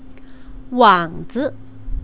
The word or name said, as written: wangzi